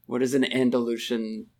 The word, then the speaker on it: Andalusian: Andalusian. And I'm pretty sure I said that right. – Yes. – Mm-hmm. – You did.